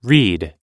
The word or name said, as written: reid